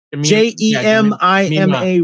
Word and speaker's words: Jemima: I uh, Kyle, speaking of cast, I was kind of crushing on uh, Jenny Crass, played by uh, Jamia J- Jamam Jamia Rooper. Is that how you say her name? – J e m i m a Rooper. Yeah. – Jemima. Jemima. Jemima.